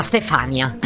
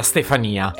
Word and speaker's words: Stefania: Se non sbaglio si chiama Stefania. – Quanti errori tutti insieme. Intanto credo che la pronuncia sia Stefania.